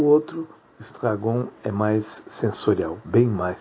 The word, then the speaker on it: Estragon: E esses dois, um é o Estragon, né, na pronúncia francesa, Estragon, que é como nós vamos falar durante a aula, e outro é o Vladimir.